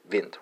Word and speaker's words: vindt: It's actually pronounced the same way. It's vint, right? Spelled V-I-N-D-T, right?